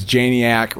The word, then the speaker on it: Janiak: Yeah, we had a big conversation, and we decided it was Janiak, rhymes with maniac.